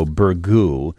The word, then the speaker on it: burgoo: This word you've been hearing on the radio, burgoo, is spelled B-U-R-G-O-O.